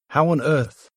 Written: how on earth

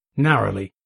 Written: narrowly

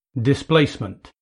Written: displacement

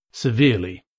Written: severely

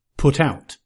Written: put out

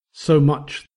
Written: so much

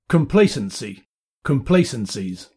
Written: complacency

